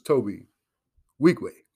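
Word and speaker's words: Tobe Nwigwe: I heard an interview the other day where he pronounced his name in the way that the Igbo tribe, the Igbo Israelite tribe, would pronounce it, which was, the N is silent. So it's Toby Wigway.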